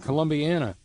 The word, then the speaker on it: Columbiana: John, thank you, in Columbiana.